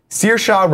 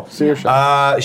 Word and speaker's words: Saoirse: Saoirse Ronan, I believe is how you say her name.